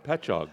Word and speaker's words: Patchogue: Patchogue. Did I say that right? – Patchog. – Patchog. – Patchog. There we go.